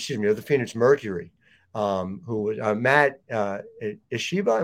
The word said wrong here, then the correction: Ishbia: Am I pronouncing that right? – I think so. It's either Ishiba or Ishbia. – Ishbia.